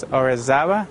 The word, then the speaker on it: Orizaba: In English, you could pronounce it as Orizaba, O-R-I-Z-A-B-A.